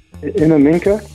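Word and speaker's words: Innamincka: Oh, how do you say that? – Inaminka. – Inaminka.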